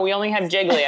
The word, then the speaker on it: Gigli: It's spelled G-I-G-L-I. – Yeah, Jiggly. – Jiggly yeah.